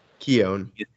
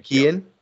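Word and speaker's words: Keoghan: Keown. – Oh, I thought it was Keon.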